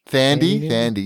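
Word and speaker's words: Thandie: Like Thady Newton, you know that actress? – Thandi, yeah.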